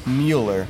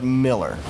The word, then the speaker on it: Mueller: Says, I thought you might want to know that Mueller, as in Louis, is not pronounced Mueller, but Miller.